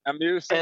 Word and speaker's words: Asmussen: That one's Steven Asmussen. I don't know how to pronounce Asmussen. – That right. – Asmussen.